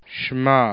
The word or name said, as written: shma